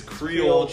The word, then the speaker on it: Creole: That Chiroli? Caroli? – Okay, first chicken. Off, this guy doesn't know how to say it. It's Creole chicken.